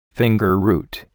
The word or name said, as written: fingerroot